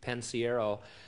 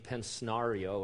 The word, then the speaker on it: Pensiero: And so he said, how do you say your last name? I said, it's really simple. It's Pensiero. I said, just let's do it phonetically. And I had him write it down phonetically, Pensiero and stuff. And then he got up and introduced me as Gene Pensnario or something.